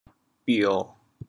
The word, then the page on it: 标: bio1; 潮阳